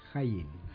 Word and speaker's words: Haijin: Haijin, que es pronuncia Haijin.